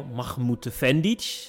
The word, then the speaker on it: Mahmutefendic: Met vandaag Anel Mahmoud Vendic. Zeg ik dat goed, Anel? Ja, je hebt het in één keer goed.